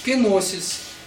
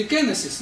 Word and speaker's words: кеносис: И вот это обнищание или самоуничижение, самоистощение называется умным греческим словом кеносис. Не знаю, кеносис или кеносис, но вот так. Кеносис, да, вот по-гречески кеносис будет ударение.